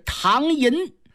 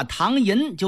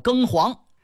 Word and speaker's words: 唐寅: 由于这薛蟠呢这水平太低了，他看那个唐字儿，以为呀是戊己庚申的庚字儿，那个寅字儿呢他以为是红黄蓝绿的黄字儿，所以说把唐寅就念成了庚黄。